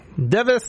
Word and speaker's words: DEV_IS: So there is no longer Hololive Japan, no longer Hall Alive English, no longer Hall Alive Indonesia, no longer Hall Alive Device, which is not spelled actually device, D-E-V-I-S. Device, device, it's pronounced device.